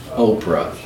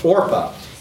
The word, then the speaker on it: Orpah: It's not Oprah. How do you say her name? It's not Oprah. – Huh? – Orpah.